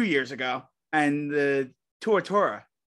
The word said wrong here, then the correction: Tuatara: I forget how to say that. SSA. The launch of that car. – The hypercar, the Tuatara. – Tuatara.